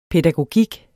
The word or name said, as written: pædagogik